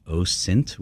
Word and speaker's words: OSINT: It's OSINT with a soft S, and the discipline has many things like that that are still somewhat in flux.